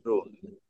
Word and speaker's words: Groen: My last name is pronounced Groen, which Groen means green, related to growing, or growing, as people pronounce it.